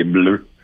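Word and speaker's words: Bleuh: Comme, tu le prononces bleu, B-L-E-U-H, c'est ça? – Oui, avec un H muet, exactement bleu.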